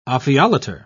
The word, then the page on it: ophiolater: British English